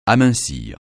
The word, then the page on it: amincir: francais